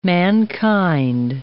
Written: mankind

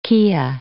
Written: kea

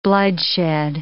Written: bloodshed

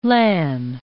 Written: LAN

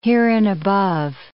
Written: hereinabove

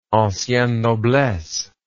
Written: ancienne noblesse

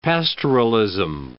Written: pastoralism

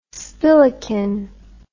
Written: spilikin

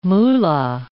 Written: moola

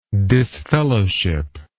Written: disfellowship

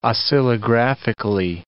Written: oscillographically

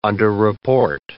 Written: underreport

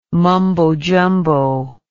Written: mumbo jumbo

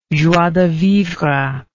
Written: joie de vivre

